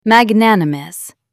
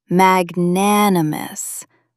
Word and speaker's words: magnanimous: Magnanimous. Mag-nan-u-mous. Meaning, big-hearted, noble, and generous, especially towards someone you just defeated. The Latin roots, magnus, great, and animus, spirit, literally mean great-souled. Stress the second syllable. Magnanimous.